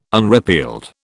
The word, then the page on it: unrepealed: British English